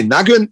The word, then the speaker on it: NAGÖN: ähm, ja, ich würde mal, also, also wie gesagt, Nagon, wie spricht man das überhaupt richtig aus? Nagon? Nee, nee, Nagon ist nur, das Ö ist nur da als äh, Respekt. – Motorhead. okay. – Nur als Respekt zu Motorhead haben wir das Ö.